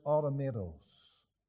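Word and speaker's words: automatos (αὐτόματος): The Greek word here is pronounced something like this, automatos, giving you the English word automatic.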